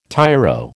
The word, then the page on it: tyro: American English